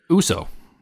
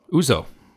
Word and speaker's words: Uso: So you used to say Uso. – Uso. – Yeah, yeah. But that's not how you say it. It's Uso.